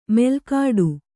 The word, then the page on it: ಮೆಲ್ಕಾಡು: melkāḍu